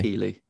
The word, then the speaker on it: Keighley: Jeff Keighley. – But it's spelled Keighley, like the northern town in the Yorkshire Dales. Keighley.